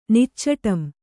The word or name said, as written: ನಿಚ್ಚಟಂ